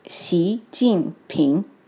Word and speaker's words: Xi Jinping: Each syllable in President Xi's name has a tone. Xi is rising, Jing is falling, and Ping is rising. What that sounds like altogether is Xi Jinping.